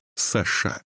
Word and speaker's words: Саша: Он представился по имени Саша, с ударением на последнее «а».